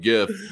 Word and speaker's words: gif: And I go hard G on gif, just come at me, I guess.